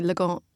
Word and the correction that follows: Lagom: La Gomme. – Yeah, it should be La, like L-A-H, and then Gomme, La Gomme.